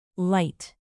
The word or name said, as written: light